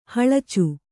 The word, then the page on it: ಹಲಚೆ: Kannada